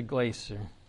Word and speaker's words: Glaser: Did you say that's the Glacier? – That is the Glacier. – How do you spell that? – G-L-A-S-E-R. Okay, so this is huge.